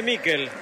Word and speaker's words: Miquel: ¿Cómo le decimos? ¿Michael o Miquel? Nicolás Álvarez, usted que es especialista en nombre. Se escribe Miquel. ¿Cómo le digo? – Decirle Miquel. – Entonces le digo a Miquel, usted me enseña y yo aprendo.